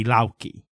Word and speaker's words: Loki: So in modern Icelandic, which is largely unchanged from medieval Icelandic, you would pronounce Thor Thaur and Loki Lauki.